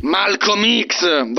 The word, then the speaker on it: Malcolm X: Come Malcolm 10. – Malcolm X.